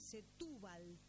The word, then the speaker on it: Setúbal: espero pronunciarlo bien, Setúbal, con acento en la U, ¿sí?